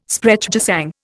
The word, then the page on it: Sprechgesang: Indian